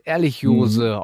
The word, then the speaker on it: Ehrlichiose: Ich habe auf meinem Zettel aber noch Anaplasmose und Ehrlichiose auf dem, ich hoffe, ich habe das richtig ausgesprochen, auf dem Zettel stehen. – Ja, perfekt.